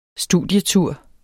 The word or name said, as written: studietur